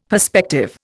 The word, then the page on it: perspective: Indian